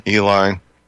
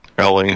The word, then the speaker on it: Eley: There's this, now the Olympic grade stuff comes out of England, and I've heard it pronounced Ellie and Eli. I think it's probably Ellie is probably the more proper pronunciation. It's E-L-E-Y, I believe. E-L-E-Y.